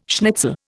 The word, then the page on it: schnitzel: Indian